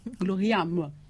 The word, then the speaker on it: gloriam: Donc, la devise de cet ordre, c'est « Ad majorem Dei Gloria ». J'ai bien prononcé? – Oui. – Gloriam, oui.